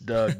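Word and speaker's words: Dug: His name is spelled D-U-G. I believe it's Doug, but I've followed some of his stat lines here recently.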